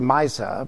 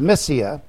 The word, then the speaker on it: Mysia: It looks like Mysa, but it's Mysia.